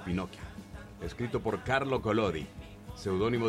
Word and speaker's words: Pinocchio: Pinocho es el personaje de una literatura italiana. La verdad que la pronunciación tendría que ser Pinocchio. Sí. Porque es italiano, Pinocchio.